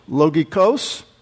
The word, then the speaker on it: logikos: There's how it's pronounced, logikos.